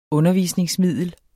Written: undervisningsmiddel

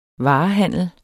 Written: varehandel

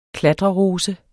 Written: klatrerose

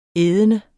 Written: ædende

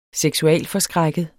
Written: seksualforskrækket

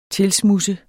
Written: tilsmudse

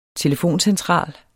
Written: telefoncentral

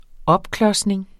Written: opklodsning

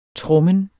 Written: trommen

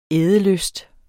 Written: ædelyst

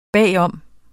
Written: bagom

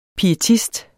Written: pietist

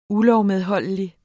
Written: ULOVMEDHOLDIGT

